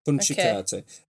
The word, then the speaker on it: puncicate: Punchicate, which is apparently means to insult an enemy by stabbing them in the buttock.